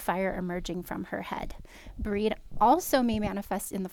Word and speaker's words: Brigid: And some people call her Bridget, just so you know. – Okay. – This is the pronunciation that feels right to me. But Bridget is great. Breed may appear in any phase of womanhood, maiden, matron, or crone.